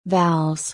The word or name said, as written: vowels